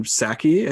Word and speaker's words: Psaki: Her name is Jen Psaki. I think the P is silent, right? – Something like that.